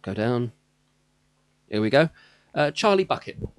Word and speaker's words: Bucket: The woman, Hyacinth, She's Hyacinth, her last name is Bucket, but she refuses to be called that. She insists it be pronounced a bouquet, mm-hmm.